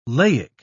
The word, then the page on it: laic: British English